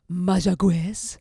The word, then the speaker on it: Mayagüez: This round was for the community of Mayagüez, or Mayagüez, as I understand the correct pronunciation to be.